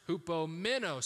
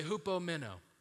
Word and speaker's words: hupomeno: And that one is pronounced hupomeno. So say hupomeno.